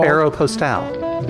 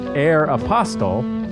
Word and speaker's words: Aeropostale: Aeropostale. – The 12-year-old claims that that word is pronounced Air Apostle, whereas Chang prefers the French pronunciation, which you just gave us.